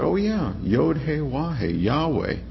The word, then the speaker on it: Yahweh: And even in their writings, they recognize Yahweh as being a more correct pronunciation.